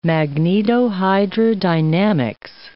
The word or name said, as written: magnetohydrodynamics